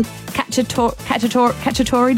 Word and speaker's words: Cacciatore: It's National Chicken Cacciatore Day.